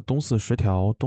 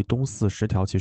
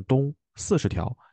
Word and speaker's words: 东四十条: 就是我们喝酒的那个地方啊，它其实在呃张自忠路，大概离东四十条其实很近。然后以前最开始我会把这个地方给念错，念成东四十条，其实不是四十，是东四空格十条。